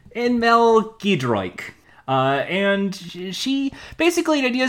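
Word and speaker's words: Giedroyc: She hosted the Great British Bake Off with her comedy duo partner, Mel Gedroik. That's how you pronounce it.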